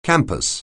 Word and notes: campus: anglais

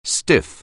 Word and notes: stiff: anglais

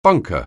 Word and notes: bunker: anglais

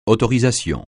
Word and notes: autorisation: francais

